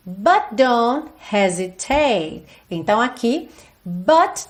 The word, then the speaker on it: hesitate: E o hesitate, como a gente falou lá na parte 2, a sílaba tônica dessa palavra é a primeira, hesitate.